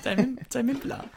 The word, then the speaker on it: Tame Impala: Tame Impala. – It rhymes with Kevin Parker.